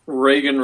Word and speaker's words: Reagan: Oh, I'm on Twitter at Reagan Ray. It's, my first name is spelled like the president, but my Twitter handle is spelled like the Reagan, R-A-Y-G-U-N.